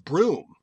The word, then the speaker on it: Brougham: and it's Brougham. As in like the vehicle. And it's a word I still can't pronounce it correctly. I just, my mind doesn't. I'm curious if you guys know how to do it. – Well, I thought it was pronounced broom.